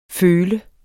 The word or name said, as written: føl